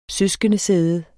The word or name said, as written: søskendesæde